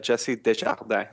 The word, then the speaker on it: Jesse Desjardins: Well, it's actually, I'm actually not Australian, but I'm French Canadian, but it's pronounced Jesse Desjardins. But you actually did it pretty good.